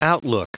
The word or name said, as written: outlook